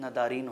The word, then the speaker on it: ندارید: جیسے ندارید ہے، وہ آج کے تہرانی لہجے میں جو اسٹینڈرڈ لہجہ مانا جاتا ہے، ندارید بولتے وقت ندارین ہو جاتا ہے، دارید دارین ہو جاتا ہے، مثلا دارد دارے ہو جاتا ہے، دارند داران ہو جاتا ہے.